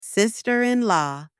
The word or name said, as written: sister-in-law